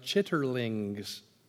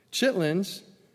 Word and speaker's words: chitterlings: People in the South here eat chitlings, or I guess the word is supposed to be pronounced chitterlings. Chitlins are pig intestines.